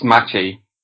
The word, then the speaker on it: Maciej: It's Maciej. – Yes. – It's a very hard sound for English people because they don't have the C in the middle.